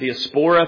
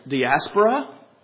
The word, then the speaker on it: diaspora: You can pronounce it diaspora if you want to. Proper pronunciation is probably diaspora.